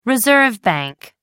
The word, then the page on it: reserve bank: American English